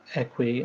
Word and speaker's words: Eqwe: Well, so the app is called Equi, and that's spelled EQWE. That name kind of, the name comes from Equi as in equidistant, and we, W-E, as in a sense of kind of togetherness.